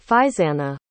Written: feiseanna

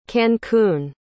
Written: cancun

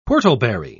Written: whortleberry

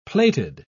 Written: plated

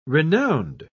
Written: renowned

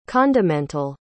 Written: condimental

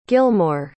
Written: gilmore

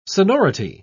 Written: sonority

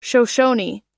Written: shoshone

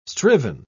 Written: striven

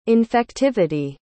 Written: infectivity

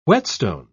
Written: whetstone

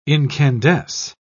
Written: incandesce

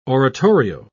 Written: oratorio

